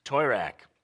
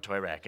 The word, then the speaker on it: Toirac: What is it? – Toirac. – All right, we got the fancy French pronunciation. Thank you. Florent Toirac.